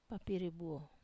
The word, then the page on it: papiri buo: wamesa